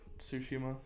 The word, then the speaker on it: Tsushima: Ghost of Tsushima? – Okay. – Or Tsushima, sorry. I know you're not supposed to pronounce the T.